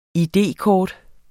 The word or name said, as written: id-kort